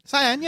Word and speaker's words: cyan: Is that how you pronounce it? – Cyan, yeah. Cyan, correct, yeah.